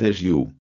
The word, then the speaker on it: hagios: Ce mot, c'est agio. Agio, H-A-G-I-O-S. On le prononce agio.